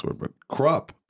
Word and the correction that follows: croup: said. – Oh, croup.